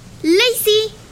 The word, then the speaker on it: Lacy: Mi nombre se escribe L-A-C-Y. Lacey,